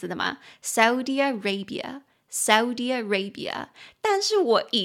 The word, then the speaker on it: Saudi Arabia: 所以它其实那个乌的音是很快的，很快就咻一下就过去的，所以沙烏地阿拉伯的英文念作 Saudi Arabia, Saudi Arabia, 它其实比较像是 Saudi Arabia 再加上 Arabia 这样子念，所以 Saudi Arabia。